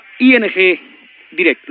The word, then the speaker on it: ING Direct: La cuenta naranja ING... Direct. Lo digo, tú me has escuchado, ¿lo digo bien? – Sí, ING Direct, efectivamente.